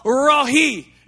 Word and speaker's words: Rohi: His name was Jehovah Rohi, is how you say it, the Lord, our shepherd.